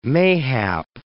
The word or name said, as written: mayhap